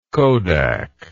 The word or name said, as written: Kodak